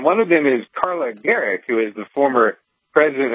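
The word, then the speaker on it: Gericke: Although I'm not sure if I'm saying her name right. – That's how I've always heard it said. Yeah. I know that there are some people that pronounce it Garicki, but I don't think that's how she would pronounce her own last name.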